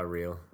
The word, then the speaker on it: Reale: And is it real or is that the correct pronunciation of your last name? – Uh, real.